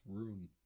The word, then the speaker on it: rune: It's rune. – Ruin? – Rune. – Rune. – Rune. – Like runescape, not ruinscape.